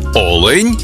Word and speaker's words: олень: і в однині, і в множині наголос падає на перший склад – олень та олені.